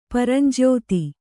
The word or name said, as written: ಪರಂಜ್ಯೋತಿ